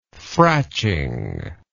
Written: fratching

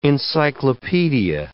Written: encyclopaedia

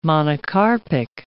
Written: monocarpic

